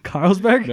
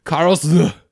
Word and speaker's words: Karlsruhe: Karlsruhe? That's not right. – Dude, take another pass at that. – Karlsruhe. – Dude, put it on your German accent, bro. – Karlsberg. – Karlsberg? – No. Karlsruhe. We're moving on. – I want to know how this is spelled. – K-A-R-L-S-R-U-H-E. – R-U-H-E.